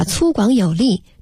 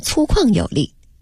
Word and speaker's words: 粗犷: 写信的人可能是一位年逾古稀的老先生，信中说，我们全家人都很感谢你不远千里来大西北演出，我们都爱听你的相声，不过你演出时念错了一个字儿，把粗犷有力念成了粗犷有力。